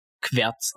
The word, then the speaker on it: QWERTZ: Q-W-E-R-T-Z, Querz ausgesprochen, im Englischen QWERTY.